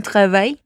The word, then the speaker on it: travail: Mais tu fais quoi comme travail? Mas você faz o que como trabalho? Vamos atentar aqui para a pronúncia dessa última palavrinha. Repete comigo. – Travail. Travail. Mais tu fais quoi comme travail?